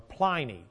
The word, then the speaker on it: Pliny: But I want to talk about what Trajan did with a fella named Pliny, or Pliny, depending upon how you pronounce his name.